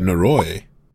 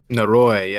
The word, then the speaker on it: Noroi: Oh, you're talking about Noroi. – Noroi, yes.